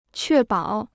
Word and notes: 确保: què bǎo

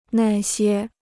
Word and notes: 那些: nà xiē

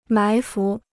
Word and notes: 埋伏: mái fú

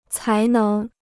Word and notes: 才能: cái néng

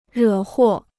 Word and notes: 惹祸: rě huò

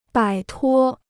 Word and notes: 摆脱: bǎi tuō

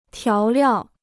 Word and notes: 调料: tiáo liào

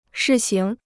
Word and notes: 试行: shì xíng

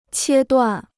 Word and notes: 切断: qiē duàn

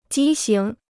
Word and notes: 畸形: jī xíng

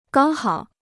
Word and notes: 刚好: gāng hǎo

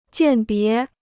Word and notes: 鉴别: jiàn bié

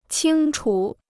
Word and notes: 清楚: qīng chu

